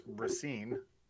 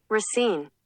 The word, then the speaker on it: Racine: Yeah, no, I would like to say that this is how you pronounce Racine. – Racine.